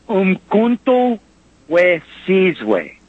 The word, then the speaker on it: Umkhonto we Sizwe: that South African political party is pronounced Umkhonto we Sizwe.